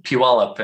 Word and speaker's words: Puyallup: Day three is Devil's Dream to, I'm going to say this wrong, North Puyallup River. Is that wrong? – Yeah, it's actually pronounced Puyallup, and anyone outside the Northwest always has trouble with that.